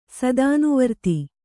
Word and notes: ಸದಾನುವರ್ತಿ: Kannada

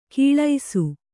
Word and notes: ಕೀಳೈಸು: kannada

